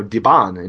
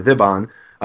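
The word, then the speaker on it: Dhiban: And, you know, you can, you know, Divon God to this day is called, you know, Diban, and, you know, same exact word, just with an Arabic pronunciation, or Diban.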